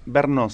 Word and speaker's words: Brno: Brno se dice. – Ah, Brno. – Brno. Sí, Brno.